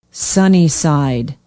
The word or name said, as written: sunnyside